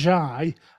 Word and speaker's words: Ghey: My understanding is it's Breonna Guy. I think it's I think it's spelled G-H-E-Y, but I do remember at the time.